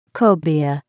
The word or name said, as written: cobia